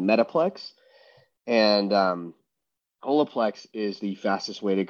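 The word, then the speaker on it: Holaplex: Yeah, so it's H-O-L-A-P-L-E-X, pronounced Olaplex.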